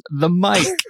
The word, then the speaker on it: Mike: My new nickname is The Mike. – The Mike. – And you might think because I'm a podcaster, I might spell that M-I-C. That's not correct. It's M-I-K-E, like the name.